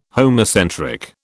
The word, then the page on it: homocentric: British English